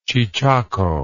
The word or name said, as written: chechako